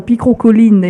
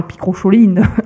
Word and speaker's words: Picrocholine: Et les guerres Picrocolline et non pas Picrocholine, d'autre part, comme certains le prononcent.